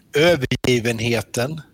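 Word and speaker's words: Övergivenheten: I'm going to butcher the name of this album. Overgiven Hetten. – Yeah, quite good. Overgiven Hetten. – Okay, close.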